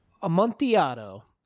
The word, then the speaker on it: Amontillado: So Amontillado, it comes from Spain. Right. So I assume Spanish pronunciation, and which would say double L is ya.